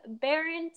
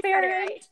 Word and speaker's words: Behrendt: We have Brooke Behrendt on the... Did I say that right? – Yes. – Brooke Behrendt.